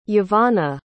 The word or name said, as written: yvonna